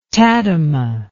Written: tadema

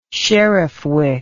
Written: sheriffwick

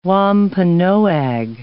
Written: Wampanoag

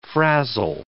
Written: frazzle

